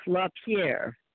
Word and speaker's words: LaPierre: I did watch the president right after of the organization, Mr. LaPierre. Do I have that correct? Do not want to say his name wrong. – No, that is correct, yeah.